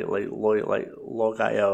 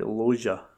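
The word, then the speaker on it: Loggia: I'm pretty sure the Loja, because I'm pretty sure when there was like a running joke in Family Guy on how to pronounce his name, because I used to always think it was like Logaya or something, whatever, but pretty sure it's like Loja or something, but could be wrong.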